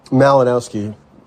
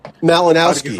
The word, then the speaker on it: Malinowski: Malinowski. Bothering, that's one way. – What'd you say? How do you say it? – Malinowski.